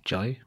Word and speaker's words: Jai Paul: Do you say Jai or Jai? – Jai.